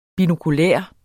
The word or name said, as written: binokular